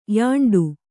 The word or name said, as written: ಯಾಂಡು